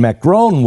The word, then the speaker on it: Macron: Macron? – Macron, yeah.